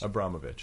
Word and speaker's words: Abramović: Am I pronouncing her name right? – I think it's Abramović. – Abramović. So Marina Abramović.